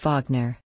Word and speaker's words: Wagner: which means the name of the mercenary company is properly pronounced Wagner.